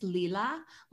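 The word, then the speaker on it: Leela: And it's Lila, like L-E-E.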